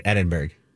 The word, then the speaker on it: Edinburgh: E-D-I-N-B-U-R-G-H.